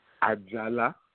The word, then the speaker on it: Ajala: Ajala.